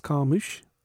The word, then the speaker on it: Carmouche: Did you say Carmucci? – Carmucci. Is that not how you pronounce her name? – It's Liz Carmouche. – Carmucci. – Just Liz Carmouche. – It's got an apostrophe over the E.